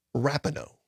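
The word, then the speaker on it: RINO: It's pronounced Rapinoe.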